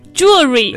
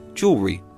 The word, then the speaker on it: jewelry: Right. Americans spell it J-E-W-E-L-R-Y, Jewelry. And I think that's the way to help you remember how to spell it or pronounce it. Jewel, J-E-W-L-Y, Re, R-Y, Jewelry.